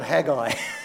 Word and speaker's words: Haggai: The prophet Haggai.